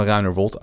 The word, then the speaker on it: Ruinerwold: Want dat waren de kinderen van, uh, van Ruinerwold uit mijn hoofd, zeg ik het goed?